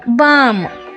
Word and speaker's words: balm: ಈಗ L silent ಇರೋ ಒಂದೆರಡು ಪದ, ಆರ್ಮ್ಸ್, ALMS, ಆರ್ಮ್ಸ್, BALM, ಬಾಮ್. ಇದನ್ನು ಬಳಸ್ತೀವಲ್ಲ ಈ ಪದ ಹೆಚ್ಚಾಗಿ, BALM, ಬಾಮ್. ಇವೆರಡರಲ್ಲೂ ಎಲ್ ಸೈಲೆಂಟ್ ಆಗಿದೆ, ಆರ್ಮ್ಸ್, ಬಾಮ್ಸ್.